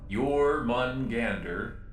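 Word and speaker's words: Jörmungandr: Jormungandr, I believe is how you pronounce that strange corporate planet.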